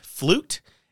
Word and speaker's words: fluyt: Some say it is a Dutch cargo ship known as a fluyt flute. As far as I can tell, it's pronounced similar to flute. It's spelled F L U Y T, mm.